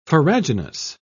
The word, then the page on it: farraginous: British English